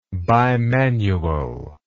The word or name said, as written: bimanually